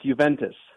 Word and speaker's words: Juventus: It's Juventus.